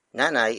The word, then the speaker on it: Nanay: It is the focus marker, pointing to the central figure of the sentence, which is nanay. Nanay. Nanay. Nanay is spelled as N-A-N-A-Y.